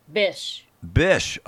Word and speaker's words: Bysshe: Percy Blythe. How do you say, it? Blythe, Percy Blythe Shelley. – Bysshe. – Oh, you say Percy Bysshe Shelley. Okay.